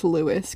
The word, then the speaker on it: Lewes: So, and also every time I read this spelling of Lewis, I want to call it lose. – How is it because spelled? – Because it's spelled L-E-W-E-S. – Oh. Yeah. That's interesting. – Yes. Yeah. So I need to like keep it straight in my head that it's Lewis, because it's like I know that it is.